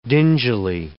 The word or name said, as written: dingily